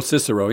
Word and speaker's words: Cicero: The oldest surviving source for the political developments of the Roman kingdom is, how do you say it in English? Cicero? – Oh, Cicero, yeah. Cicero.